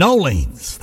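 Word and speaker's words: New Orleans: From Nolens, that's how they say it down there.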